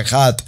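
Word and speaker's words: Ejad (אחד): La palabra Ejad tiene que ver con unidad, unidad, dígalo conmigo, unidad y Ejad como se pronuncia Ejad.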